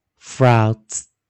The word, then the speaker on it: frauds: 哦，有很多技巧啊，如果愿意学的话啊，fro 啊前面念 fro，这个 o a u 啊破音的时候我们念 o o o，那 d s 记得我这边拿旁边一点 z。 Frauds, frauds, frauds，注意老师怎么念？Frauds, frauds, frauds。